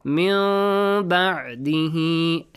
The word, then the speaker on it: مِن بَعْدِهِ: You were correct, but you didn't extend it. مِن بَعْدِهِ A nasal sound?